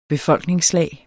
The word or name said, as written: befolkningslag